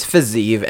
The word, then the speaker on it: Fiziev: Today we're going to be looking back at Rafael Fiziev. Is it Fiziev or Fiziev? – It's Fiziev. Everyone says Fiziev.